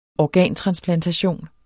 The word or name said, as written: organtransplantation